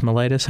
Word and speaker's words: mellitus: Mellitus, how do you say that? – Diabetes mellitus, yes.